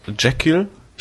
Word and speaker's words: Jekyll: Ja, zum einen habe ich angefangen mit Jekyll.